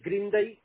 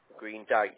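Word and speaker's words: Greendykes: I moved on to Greendike. – Greendike.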